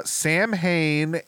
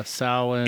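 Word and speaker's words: Samhain: Sam Hain and All Hallows Eve are the focus, but unfortunately, Sam Hain is pronounced incorrectly. What is it? Sawin? Sawin or something like that. Yeah. Sawin.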